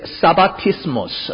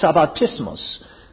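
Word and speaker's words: sabbatismos (σαββατισμός): In fact, in Greek word, if I just read the word, its pronunciation is something like this, sabbatismos, sabbatismos.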